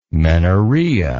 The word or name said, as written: menorrhea